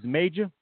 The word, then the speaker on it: Mayjah: All right, so uh, name of this joint is called Morning Comes, and this is Major, spelled M A Y J A H, but pronounced like the word major, M A J O R.